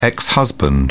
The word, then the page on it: ex-husband: British English